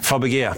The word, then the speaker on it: Fabege: Men han har också satt pengar I Fabege är det som man uttalar det I Sverige? – Fabege, precis.